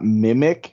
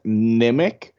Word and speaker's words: Nimic: It's not Mimic. You keep saying it with an M. It's Nimic, with an N.